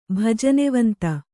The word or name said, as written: ಭಜನೆವಂತ